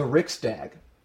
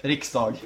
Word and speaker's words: Riksdag: The parliament is referred to as the Riksdag, which, I might be pronouncing that incredibly wrong. – Sweden? – Yes. – Did I get it right? – Riksdag. – Riksdag. Damn it.